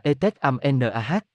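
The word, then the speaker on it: Itzamná: Ikyamna, phát âm là Etet Amnah và đánh vần là Ikyamna, là vị thần tối cao trong thần thoại Maya.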